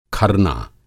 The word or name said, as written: खरना